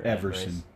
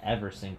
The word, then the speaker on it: Everson: But I was reading online, and like the Vikings had posted like text messages between them and Emerson Griffin, or yeah, if I said that right, or Everson. Like Ever. – Yeah, Everson Griffin.